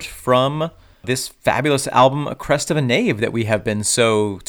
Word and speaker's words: Crest of a Knave: but Crest of a Knave rhymes with Crest of the Wave.